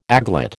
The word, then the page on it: aglet: American English